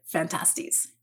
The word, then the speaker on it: Phantastes: There are multiple different ways to pronounce Phantastes, but the majority of MacDonald scholars do pronounce it Phantastes.